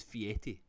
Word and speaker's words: Fieri: It's spelled Fieri. I will not disagree with that. But listen to him say his name. He says Fieri.